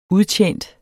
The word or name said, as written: udtjent